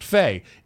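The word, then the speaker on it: fey: Faye, adjective, pronounced Faye.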